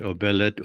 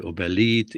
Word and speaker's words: Obelit: And so we lived in Tehran, and even there, people had difficulty pronouncing Obelit, Obelit, you know, an Assyrian at the house, it was Obelit. Mm-hmm. But outside it was Obelit.